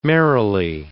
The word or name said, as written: merrily